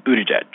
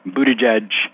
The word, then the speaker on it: Buttigieg: So you guys, it's Buttigieg. Buttigieg, for the love of God, please, Buttigieg.